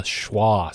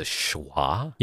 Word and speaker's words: schwa: You know, we learned recently about the schwa sound is what it's called. – The schwa? – Yeah. It's spelled S-C-H-W-A.